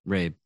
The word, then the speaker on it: Rabe: In 2005, John Rabe, man, I have butchered his name last time. I'm butchering his name this time. – Rabe, okay.